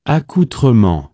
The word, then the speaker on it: accoutrement: Here's who we actually have to ask. This guy will know the best. – Accoutrement. – Yes! Thank you!